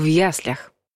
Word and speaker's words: яслях: И третье, ударение во всех падежах падает на первую букву «ребенок в яслях».